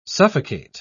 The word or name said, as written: suffocate